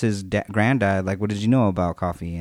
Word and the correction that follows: Kofi: And he was like... – Kofi. – Kofi, sorry. But it's all good.